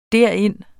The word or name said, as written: derind